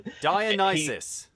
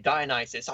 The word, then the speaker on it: Dionysus: I keep getting Dionysus. – Thank you, Dionysus.